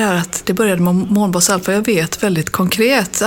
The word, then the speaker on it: Månbas: Mina föräldrar kommer från Tjeckien, så de uttalade det ju fel. Det ska ju vara Månbas. Alfa. – Just men det var Mombas. – Månbas Alfa, ja.